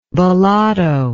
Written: Bellotto

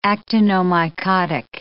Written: actinomycotic

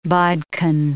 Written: bodkin

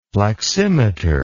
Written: pleximeter

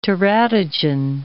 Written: teratogen

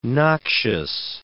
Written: noxious

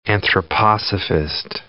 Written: anthroposophist

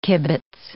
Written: kibitz

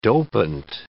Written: dopant

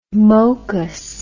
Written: mokus

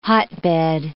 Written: hotbed

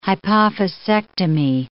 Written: hypophysectomy

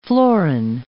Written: florin

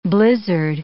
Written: blizzard

